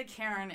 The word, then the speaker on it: Charon: And when I say Karen, it's like Charon. That is how his name is spelled.